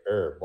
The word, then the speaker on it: herb: As long as you don't say herb, like a lot of people say herb, and they don't pronounce the H.